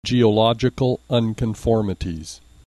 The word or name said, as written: geological unconformities